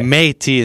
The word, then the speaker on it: Métis: Well, no, in French... The accent aigu gives the E sound an A sound. – Oh, okay. – Métis instead of Métis.